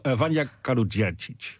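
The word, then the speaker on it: Vanja Kaluđerčić: Dus we hebben nu over de Kroatische Vanja Kaludjeri. – Vanja Kaludjercic. – Kijk, jij kan het beter uitspreken.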